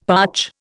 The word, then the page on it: parch: Indian